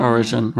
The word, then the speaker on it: Origen: Am I saying that right? Origen. – Origen, I think. – Origen, yeah. Right.